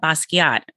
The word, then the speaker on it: Basquiat: But you also have Basquiat.